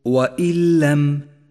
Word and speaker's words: وإن لم: وإن لم، حيث جاءت النون الساكنة وجاء بعدها حرف اللام وهو حرف إدغام بلا غنة، فالواجب هنا أن ندغم النون الساكنة بحرف اللام وأن يسقط لفظها بشكل كامل، ثم نشدد الحرف الذي بعدها وهو اللام من دون غنة، ولذلك لونت النون الساكنة باللون الرمادي في مصحف التجويد إشارة إلى عدم نطقها، ووضعت شدة على حرف اللام الذي بعدها.